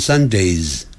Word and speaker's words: Sundays: 但如果说 on Sundays 加了个 s，那个念成 z， on Sundays，那就是每逢礼拜天的意思。